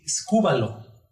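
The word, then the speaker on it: escúbalo (σκύβαλον): Basura es una palabra griega que se pronuncia así, escúbalo. Escúbalo, lo que se tira a los perros, eso quiere decir basura.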